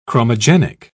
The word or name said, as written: chromogenic